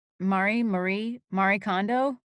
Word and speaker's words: Marie Kondo: Marie Kondo? – Mm-hmm, yes.